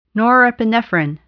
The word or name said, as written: norepinephrine